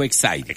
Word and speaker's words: Exide: se acuerda que ven unas baterías Exide o Excite, como quiera, Hormuz u Hormuz, dentro... – Se escribe Exide, se pronuncia Excite, dentro decía el comercial de las baterías.